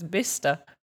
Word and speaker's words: Bicester: Very close, it's Bicester. Bicester.